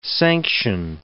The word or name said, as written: sanction